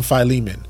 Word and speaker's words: Philemon: He's about to send Onesimus back to Philemon, however way you want to pronounce his name. I grew up calling him Philemon.